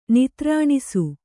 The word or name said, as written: ನಿತ್ರಾಣಿಸು